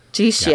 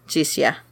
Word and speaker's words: Jixia: Jixia with the X is the sh. So now you know. Anytime you see like a Chinese word, X is shh.